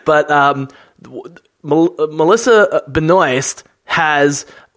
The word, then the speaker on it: Benoist: Benoist.